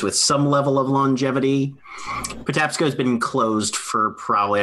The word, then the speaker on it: Patapsco: How do you spell that one? – P-A-T-A-P-S-C-O. – Patapsco, okay. – Patapsco. – Oh, P-S-C-O. – Yes, P-S-C-O.